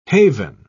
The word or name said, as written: haven